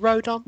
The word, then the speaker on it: Rodon: Rodon. Is it Rodon? – Yeah. – I keep wanting to call him Rondon. – I'm going to say some other variation of Rodon there.